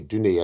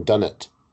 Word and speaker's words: Dunnett: I always refer to as Dorothy Dunay, and I notice you're saying Dunnett. Is that the correct pronunciation? – I believe so.